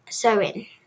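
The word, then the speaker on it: Samhain: Halloween's origins date back to the ancient Suri festival of Shamhain, pronounced Sowin.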